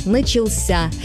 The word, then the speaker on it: начался: А вот в форме прошедшего времени мужского рода ударение уходит в конец – «начался».